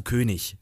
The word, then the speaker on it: König: Und die bringen dir erstmal so Aussprachetechniken bei, wie wenn du König, also mit IG hast, sagst du König, weil IG wird zu ich.